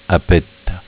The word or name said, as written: A petta